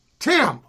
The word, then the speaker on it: Thymme: How would you say it? – I would pronounce it Tim. – Tim, okay.